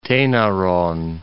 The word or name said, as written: Tainaron